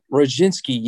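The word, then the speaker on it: Rzepczynski: What is it, Mark Rizhinsinski? – Oh, Roginski. Yeah, I remember him.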